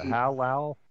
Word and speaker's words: Halal: H-A-L-A-H-A-L. – I see how it's spelled, but Halal? – Halal.